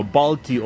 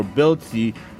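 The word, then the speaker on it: Bălți: And Romania is, guys, a lot cheaper than, I'm sorry, not Romania, I'm in Moldova, as you guys know, Balti or Belci, that's how you pronounce it.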